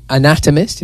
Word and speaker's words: anatomist: He is one of the most highly acclaimed English architects in history, as well as an autonomist, or an anatomist, if you pronounce it correctly, an astronomer, a geometer, and a mathematician physicist.